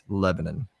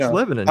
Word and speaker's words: Lebanon: there is no place called Lebanon in the state of Tennessee. It's Lebanon. – Oh, so I said it right the first time. – You said it right the first time. I was like, wow, you are a Southerner after all. And then you turned it into something. I mean, just in case someone was like, that's not how you say it. That's not how you pronounce it.